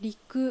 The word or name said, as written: Riku